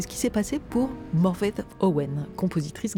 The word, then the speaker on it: Morfydd: Je ne vous garantis pas ma prononciation à 100%, mais alors que son nom s'écrit Morfydd, Y2D, F-Y2D, on prononcerait quelque chose comme Morwedd.